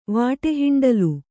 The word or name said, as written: ವಾಟೆಹಿಂಡಲು